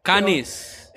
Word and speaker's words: Cannes: ¿Cómo se pronuncia? ¿Alguien sabe francés? – Cannes. – Eso, en el Festival de Cannes. – Como cuando uno echa los canes.